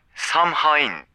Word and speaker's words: Samhain: Şimdi bu festival Samhain diye yazılıyor ama Samhain diye okunuyor. Fakat gördüğünüzde tanımanız için biz bütün podcast boyunca Samhain diyeceğiz. Yoksa biliyoruz biz de nasıl okunuyor. Samhain diye okunuyor. Samhain diye okunmuyor. Biliyoruz.